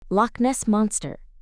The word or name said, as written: Loch Ness Monster